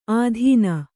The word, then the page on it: ಆಧೀನ: ādhīna